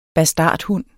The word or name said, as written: bastardhund